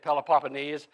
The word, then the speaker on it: Peloponnese: But I'm saying it the proper way, at least the way the dictionary folks say that it's to be said, Peloponnese, and the mainland.